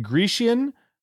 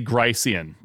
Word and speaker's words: Gricean: So how do you say, is it Grecian? How do you say the maxims? – H. Paul Grice. So I pronounce Grice. It Gricean maxims from the Stanford Gricean maxims.